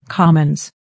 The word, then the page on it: commons: kɑ́məns; コォモンス